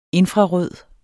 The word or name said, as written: infrarød